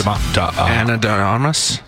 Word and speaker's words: Ana de Armas: Probably start with trying to say her name properly. – Ana de Armas? Armas? Ana de Armas? – That sounds about right.